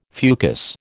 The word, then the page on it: fucus: American English